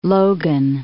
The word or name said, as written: Logan